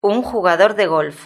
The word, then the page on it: un jugador de golf: Spain Spanish